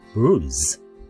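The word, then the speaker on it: blues: これは、英語では、ブルーズ。ね。ブルーズ。はい、ブルーズ。ね。 br の発音がね、出だしがちょっと難しいというね、話をしておりました。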